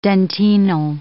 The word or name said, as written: dentinal